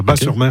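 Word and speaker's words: Batz-sur-Mer: Et surtout, la crêperie s'appelle le Dervin à Bas-sur-Mer, qui font les meilleures crêpes de la domaine. – Oui, Bat, moi. Ouais. On prononce Bas, le T ne se prononce pas. Bas-sur-Mer.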